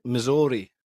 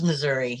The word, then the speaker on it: Missouri: You definitely don't pronounce it as Missouri, do you? Is it Missouri? – It's Missouri, yeah. – Missouri. Okay.